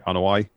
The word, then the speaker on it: Anoa'i: Zoe Kinrana, who else in the Anoa'i? That's how you pronounce the name, right? Anoa'i? – Yeah, so.